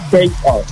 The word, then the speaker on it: Chaos: que se llama Chaos, es decir, sí, 4, número 4, Os, pero se pronuncia Chaos.